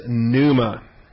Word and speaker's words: pneuma: So point four is pneuma. P-N-E-U-M-A. It's a silent P, pneuma.